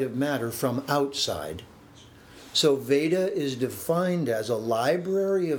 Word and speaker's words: Veda: Veda. – Veda. – Mm-hmm. And what you said when you said Vedra is very useful, because words morph and change as they move from one locale to another.